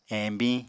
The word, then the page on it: eñbiñ: Apatani